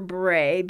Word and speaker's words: Brae: B-R-A-E, pronounced Bra. Wait, I have to get through this. I know it sounds like bra, but I love it. My husband says I could name her Bray, B-R-E-Y, and spell it B-R-A-E, but I don't know.